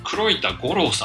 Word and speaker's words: 黒板五郎: まあ、塾講師とか予備高校師は、え黒板って書くと黒板って読んでしまう恐れがありますが、黒板五郎さんとやるしか、